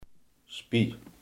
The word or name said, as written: speeksel